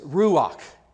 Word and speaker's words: ruach: So as we just read that passage in the Hebrew Bible, referring to the Holy Spirit, the Hebrew word that is used is ruach. And it really has a much more guttural sound at the end, but I don't want to make it sound like I'm, coughing up a fur ball or something in here. But the word is ruach.